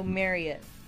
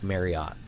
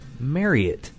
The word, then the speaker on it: Marriott: The Marriott team says that if you're pronouncing Marriott correctly, it should rhyme with chariot. – So Marriott. – Most people seem to pronounce it with a hard ot, like otter, like Marriott. Like what did I say, right? – Mm-hmm. – But you're supposed to pronounce it Marriott. – Mm. I'll try to remember that. – Like chariot. It's Marriott.